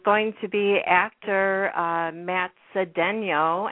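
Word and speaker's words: Cedeño: It's Cedeno, because I saw that little accent over the end.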